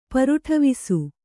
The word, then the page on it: ಪರುಟವಿಸು: paruṭavisu